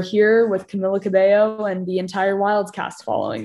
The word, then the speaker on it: Camila Cabello: So it is Camila Cabello. Camila Cabello.